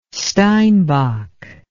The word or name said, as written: steinbok